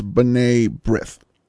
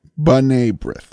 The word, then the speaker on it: B'nai B'rith: I finally figured out he was trying to say, and I'm going to butcher it, please, apologies, Benai Berith, the Jewish charitable organization. The pronunciation, as I heard it on New York airwaves growing up, was B'nai B'rith. B'nai B'rith.